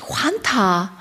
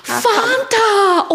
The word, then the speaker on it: Fanta: Dann hat sie hingezeigt und ich so, Fanta, Oida, bitte richtig aussprechen.